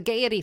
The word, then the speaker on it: Gaiety: Is that how you pronounce it? – It's the Gaiety Theater, the historic Gaiety Theater.